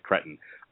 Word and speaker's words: cretin: You know, I know it's pronounced cretin, but I like cretin.